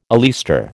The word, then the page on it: oleaster: American English